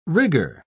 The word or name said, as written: rigor